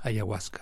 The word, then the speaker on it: ayahuasca: And the drug that you drink, am I saying this correctly? It's ayahuasca? – Ayahuasca.